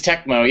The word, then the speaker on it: Tecmo: Yeah, isn't it Tecmo or is it Temco? – I don't know. – It's Tecmo, yeah.